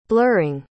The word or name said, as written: blurring